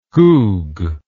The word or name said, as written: goog